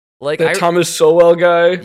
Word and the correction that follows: Sowell: Thomas Soul. Soul. – Is that how you say it? – Oh, yeah, come on.